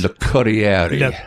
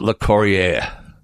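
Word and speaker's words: Locorriere: Because he didn't pronounce that name himself, because when he got down to Texas and hooked up with Dr. Hook, no one in that state could pronounce his name. They kept getting it wrong. So he just said, oh, look, just pronounce it Le Corriere. Because it's Italian for the courier.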